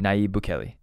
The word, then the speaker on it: Nayib Bukele: I can never say his name, Nayib Bukele.